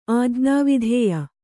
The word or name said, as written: ಆಜ್ಞಾವಿಧೇಯ